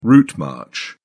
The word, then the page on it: route march: British English